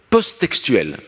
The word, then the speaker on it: post-textuelle: post-textuelle ». Post-textuelle, ça se prononce comme ça, avec un seul T, post-textuel.